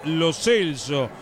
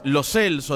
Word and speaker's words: Lo Celso: le dijimos toda la vida Gio Lo Celso y resulta que es Lo Celso, en la etapa, en la cita de pronunciaciones que realizó la FIFA, ¿no, tanque? – Sí, sí, tal cual, va a costar, pero es Lo Celso, de aquí en más.